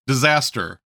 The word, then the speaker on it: Dzastir: This time, she decided to flirt with the orc bard, and his name is Disaster, but it is spelled D-Z-A-S-T-I-R, and they loved that.